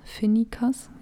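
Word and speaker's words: Finikas: Finicas, ja, so spricht man es aus.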